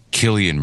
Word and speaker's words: Cillian: It's Cillian Murphy with a hard C. Killian Murphy, it's Irish.